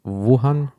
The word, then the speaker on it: Wuhan: Wuhan? Wuhan.